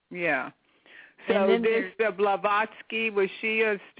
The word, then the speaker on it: Blavatsky: Blavatsky.